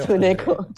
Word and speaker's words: Tsuneko: Tsuneko. es como tsunami, tsunami se escribe con TS, Fran, TS, Tsuneko.